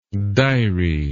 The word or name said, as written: dirae